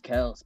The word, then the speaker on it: Kelce: Is his name really Kelce? – Yeah, that's what he's like. It's actually pronounced Kelce, but I just never told him.